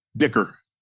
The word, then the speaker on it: Dicker: That kick by Dicker, is it Dicker or Decker? I don't want to pronounce it right. – Dicker.